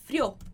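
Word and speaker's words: frió: No frio, a gente tem só uma sílaba. Então, a parte mais forte vai acabar caindo no O. Frio. A gente quase não solta esse I. Frio.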